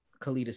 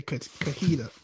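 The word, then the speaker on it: Kellita: Kalita Smith. – Yeah, I don't know how to pronounce that, so I was gonna say Kahita.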